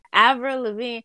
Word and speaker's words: Avril Lavigne: Avril Lavigne.